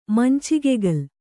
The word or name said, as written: ಮಂಚಿಗೆಗಲ್